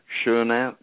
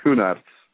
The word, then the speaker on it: Schoenaerts: The main character in the movie is portrayed by Matthias, and I'm sure I will get his last name wrong, Schoenaerts. – Yes, it's very well. That's, that's the way it's I knew I would murder it. – It's Schoenaerts. But I do believe it's better to say Schoenaerts.